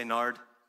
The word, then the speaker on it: nard: Say nard. How many of you go to the mall to go buy some nard? Here's the reality, though, that word rhymes with lard.